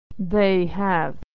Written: they have

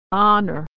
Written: honor

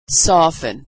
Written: soften

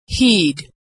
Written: heed

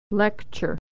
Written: lecture

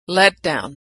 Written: letdown

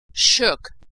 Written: shook

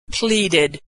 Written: pleaded

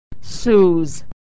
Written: sues

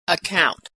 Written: account

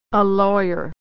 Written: lawyer

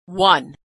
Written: won

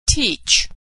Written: teach

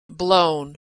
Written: blown

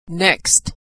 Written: next

